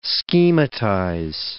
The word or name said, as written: schematize